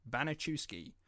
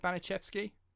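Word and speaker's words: Banaszewski: So, I've been saying Banachewski because that's how I assumed Americans would say it, because there's quite a few Polish words that have a W in it, which should be pronounced as a V, but a lot of Americans say it with a W.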